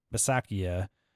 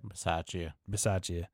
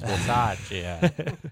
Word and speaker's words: Bisaccia: Raiders coach Rick Bisaccia said that. – Bisaccia. – Oh, excuse me. – Yeah, don't be a Bisaccia. I don't have no respect. Pronounce it right. It's Bisaccia.